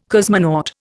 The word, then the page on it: cosmonaut: Indian